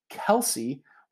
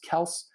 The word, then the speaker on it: Kelce: Travis Kelce, which we were recently educated that that is not how you pronounce his last name, and that everyone in all of the media and even his teammates like Patrick Mahomes have been pronouncing it wrong. It is actually Travis Kelce. Just doesn't sound the same.